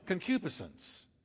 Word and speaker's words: concupiscence: Here's one, concupiscence. I've heard that called all kinds of things. Concupiscence is the pronunciation.